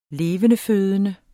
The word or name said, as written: levendefødende